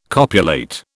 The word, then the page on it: copulate: British English